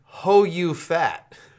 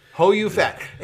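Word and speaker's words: Ho You Fat: Well, his last name is Ho-U-Fat, and it's spelled H-O space capital Y-O-U space F-A-T. Ho-U-Fat.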